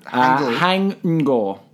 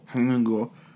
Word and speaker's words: Haing Ngor: Hang Ngo. – Hang Ngo. Okay, so he's 1985. H-A-I-N-G-N-G-O-R.